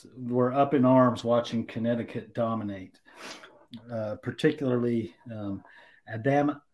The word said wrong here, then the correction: Adama: I got to say his name right, Adama Sanogo, who played for Connecticut, who was pretty dominant over this whole tournament.